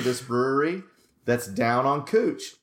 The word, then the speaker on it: Couch: Okay, so apparently in Portland, that is not how you pronounce it. And in Portland, couch, that word is pronounced cooch.